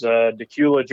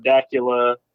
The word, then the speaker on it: Dacula: My hometown is Decula, Georgia. A lot of people mispronounce the name of my hometown. I have heard Dacula, Decula, Dracula on the news before, local news.